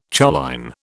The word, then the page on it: choline: British English